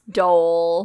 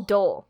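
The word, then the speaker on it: dhole: But it's pronounced Dole. – Dole.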